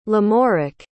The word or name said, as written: lamorak